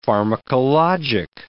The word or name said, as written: pharmacologic